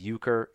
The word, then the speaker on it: Euchre: It's spelled Euchre, so it's like the Catholic Eucharist, but it's Euchre.